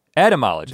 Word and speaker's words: etymologists: Etymologists. – They knew that before Jenna had to tell us, and somebody's mispronouncing it. – It's not N, there's no N in it.